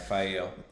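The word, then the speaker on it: Faile: He, in the next chapter, so basically they leave Remen, and this is when Fael. How do you say it? – Yeah, Fael.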